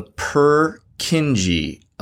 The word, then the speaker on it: Purkinje: But it's called the Purkinje effect.